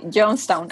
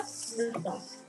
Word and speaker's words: Johnstown: ¿Así se pronuncia? Sí, ¿verdad? – Sí, Johnstown, ajá. Johnstown.